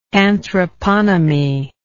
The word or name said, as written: anthroponomy